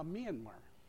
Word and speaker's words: Myanmar: And now they even changed the pronunciation. It's now Myanmar.